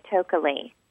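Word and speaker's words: Tokeli: It's T-O-K-E-L-I, Tokely. – Tokely. – It's Potawatomi Indian. The original word is Tokwali, and I believe that Tokwali means I love you in Potawatomi Indian.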